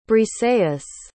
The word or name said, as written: briseis